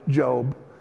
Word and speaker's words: Job: It's spelled like Job, but it's Job.